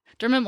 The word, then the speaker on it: Dermot: Yeah, Dermot.